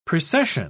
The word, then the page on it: precession: British English